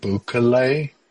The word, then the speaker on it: Bukele: His name is, I think, Bukele, is how it's pronounced.